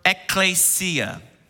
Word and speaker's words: ekklesia: It's the Greek word ekklesia. I always emphasize clay as I pronounce it because I think about the potter's hands forming his clay.